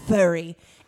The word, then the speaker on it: Fury: It's pronounced furry. Anyway, listen. – It's spelled Fury.